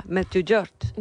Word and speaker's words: Medjugorje: Medjugorje.